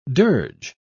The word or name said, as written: dirge